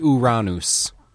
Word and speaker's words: Uranus: There's the Uranus the Mystic. No harpsichord in that. – Uranus. – Uranus. Fine. We'll use the Greek pronunciation since it's a Greek name anyway. Uranus.